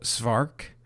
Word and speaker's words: Szwarc: Jeannot Svark is the French director. Yes. Of this film. – S-Z-W-A-R-C. – Yeah, it's basically like Schwartz, is my understanding.